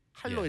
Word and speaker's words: 한로: One is 그 할로라고 했죠. 할로? 한로? 한, 할로? 할로 yeah. 이렇게 할로 할 때 그 할로.